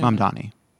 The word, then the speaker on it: Mamdani: Mamdani.